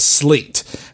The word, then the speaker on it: Sleat: So now we're moving on to the Slate Peninsula, which is spelled S-L-E-A-T, but is pronounced as Slate,